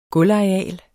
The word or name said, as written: gulvareal